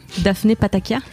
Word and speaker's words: Daphné Patakia: Est-ce que je prononce bien ton nom Daphné Patakia Oui, mais mon nom entier est Daphné Ioakimidou Pataka.